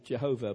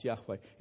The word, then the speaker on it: Yahweh: Or you might want to pronounce it Jehovah, but it's more likely to have been pronounced Yahweh. It is Yahweh.